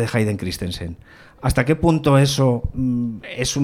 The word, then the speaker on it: Hayden Christensen: Aparece el personaje de Darth Vader, de Anakin, de Hayden Christensen.